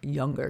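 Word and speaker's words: Junger: Soft J, Younger. So it's J-U-N-G-E-R.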